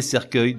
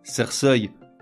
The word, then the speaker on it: cercueil: Je m'explique, avec une terminaison en EUIL, orgueil se prononcerait orgeuil » et cercueil donnerait cerceuil ».